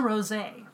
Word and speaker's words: rosé: It's a rosé. It's a rosé. We're drinking a rosé because it is... – Not pronounced rose.